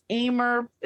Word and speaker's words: Ahmer: And it's Ahmer. It's my last name, but it's spelled A-H-M-E-R. So it looks a little bit different, but it does rhyme with gamer.